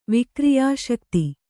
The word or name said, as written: ವಿಕ್ರಿಯಾಶಕ್ತಿ